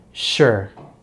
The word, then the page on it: sure: American English